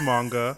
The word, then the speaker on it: manga: Yes, it is manga.